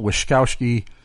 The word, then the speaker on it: Wyszkowski: Michael Wyszkowski, sounds like I have a lisp, but that's how I believe you correctly pronounce it.